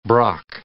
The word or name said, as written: brock